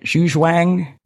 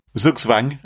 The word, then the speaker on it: Zugzwang: Zhu Zhuang? – Zugzwang.